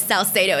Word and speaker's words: Salcedo: It is Salcedo, exactly the way you said it.